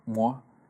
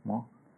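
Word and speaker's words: Mgła: Me hace recordar mucho y muy específicamente a, este grupo polaco eh, se pronuncia MOA, es MGLA, ¿no? MOA.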